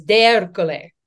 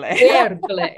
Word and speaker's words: D'Ercole: Okay, so it means of Hercules in Italian. I know. – That's awesome. I love it. – It's dramatic. I'm dramatic. It fits. And the proper Italian pronunciation is D'Ercole.